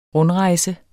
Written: RUNDREJSEN

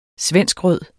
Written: svenskrød

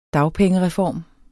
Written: dagpengereform

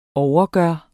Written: overgøre

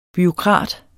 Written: BUREAUKRATEN

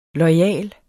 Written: loyal

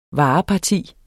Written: vareparti

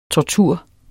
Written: tortur

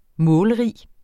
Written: målrig

